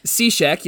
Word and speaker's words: Cishek: Cishek, yep.